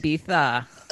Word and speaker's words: Ibiza: I mean, if there was a spring break in the UK, I mean, British people really like to go to Ibiza, as they pronounce it. – Ibiza.